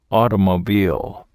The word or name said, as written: automobile